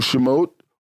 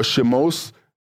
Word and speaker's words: Shemot: And the name for the book of Exodus come from the Hebrew Shemot or Shemos, which is how it's pronounced in some translations, which in the English is translated Exodus.